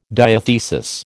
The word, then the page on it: diathesis: American English